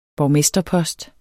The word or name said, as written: borgmesterpost